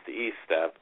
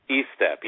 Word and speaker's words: Estep: Yes, actually it's English. It's pronounced Estep, but it's a common mistake. – I'm sorry. – Yeah, Estep. Yes, long E. Sorry. But most of the time it's pronounced Estep.